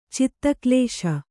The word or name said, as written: ಚಿತ್ತಕ್ಲೇಶ